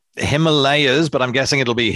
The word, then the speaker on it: Himalayas: So I've heard Himalayas as well as Himalayas before. – Yeah. So from what I understand, it's meant to be pronounced Himalayas.